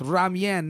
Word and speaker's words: ramyeon: Ramen, noodles. That's how you pronounce it, boys. If you're ever with an Asian girl, it's ramyeon, not ramen.